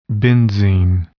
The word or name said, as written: benzene